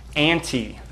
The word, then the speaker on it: anti: It's the word that you pronounce, anti.